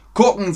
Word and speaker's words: gucken: Gucken sagt man im Norden vor allem. Oft wird der erste Buchstabe wie ein K ausgesprochen, also wie gucken.